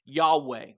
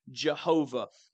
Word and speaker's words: Yahweh: Yahweh is the way it's often pronounced, or Jehovah.